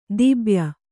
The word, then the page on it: ದಿಬ್ಯ: dibya; Kannada